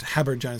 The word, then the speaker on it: habergeon: I had no idea what a habergeon was, or Habergian, but it's clearly pronounced Habergian. It's clearly trisyllabic, that word, the way that he uses it in this poem.